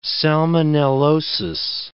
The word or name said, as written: salmonellosis